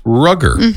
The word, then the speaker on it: Rogger: Okay, and if you just saw his name written out, you'd probably think his name was Roger. R-O-G-G-E-R. But it's pronounced Rugger.